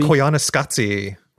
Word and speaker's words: Koyaanisqatsi: Koyaanisqatsi.